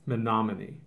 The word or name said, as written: Menominee